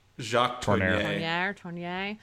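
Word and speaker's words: Tourneur: Jacques Tornier.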